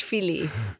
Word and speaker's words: filet: So, and these fish filet, do you pronounce the TS on the end of that? – Yeah. Fish filet, nein. – Fish filet. Okay. – We try French here. Yeah. fish filet.